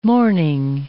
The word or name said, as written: mourning